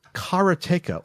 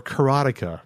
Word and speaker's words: Karateka: And then I guess the larger mini topic from this is that they are doing a physical release of Karateka, or Karateka, if you want to pronounce it either way.